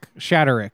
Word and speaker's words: Shaderick: So this guy's name is Shaderick Jones. Shaderick. Shade. It's spelled literally Shade Rick.